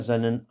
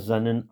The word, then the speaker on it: sein: Dass er seinen eingeborenen Sohn gab. Remarquons les EI de sein et de eingeborenen. EI, c'est toujours I en allemand. Auf das alle, die an ihn glauben. Rien de nouveau, sauf le in, I-H-N,